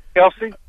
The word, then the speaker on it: Elsie: Elsie. – Elsie. – E-L-S-I-E.